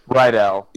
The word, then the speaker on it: Rydell: I'm not even sure if I'm saying it correctly. R-Y-D-E-L. – Rydell, yeah.